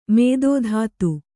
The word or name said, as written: ಮಧುದೂತಿ